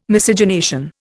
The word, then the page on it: Miscegenation: Indian